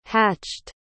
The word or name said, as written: hatched